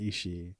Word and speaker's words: Ishii: Like Ollie, for example, will say Ishii. I'm like, listen to how the Japanese announcers say his name. They say Ishii. Like, you extend it, but you just don't say it as a separate like vowel at the end.